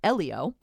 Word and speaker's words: Elio: Elio. – Mm-hmm. – Here's the... Did I say it right? – You did.